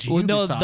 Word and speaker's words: Ubisoft: Well, no, they've come out and said it's Ubisoft.